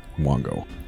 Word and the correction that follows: Wango: Wango, yes. Oh, we've been mispronouncing it this whole Wango.